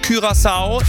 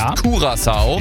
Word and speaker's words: Curaçao: Hallo, und wir haben schon mal rausgefunden, es heißt Curaçao, nicht Curaçao. – Richtig. – Auch wenn das der eine oder andere vielleicht denken mag, Curaçao ist die richtige Aussprache.